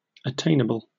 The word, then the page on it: attainable: British English